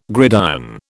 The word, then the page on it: gridiron: British English